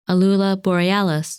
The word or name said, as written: Alula Borealis